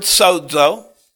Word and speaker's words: sozo: The Greek word sozo, spelled, the transliteration is S-O-Z-O. The phonetic pronunciation is S-O-D-E. With the emphasis on the first syllable, S-O-D-E-Z-O, sozo.